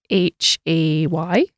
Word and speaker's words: hay: It sounds like one of these silent H scenarios to me, Hector, so I'm going to guess H-A-Y.